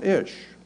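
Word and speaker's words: ish (אִישׁ): Man is pronounced ish.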